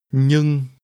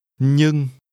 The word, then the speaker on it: nhưng: Nhưng. Nhưng. Nhưng. – This is pronounced with a mid-level tone. Say, but. – Nhưng.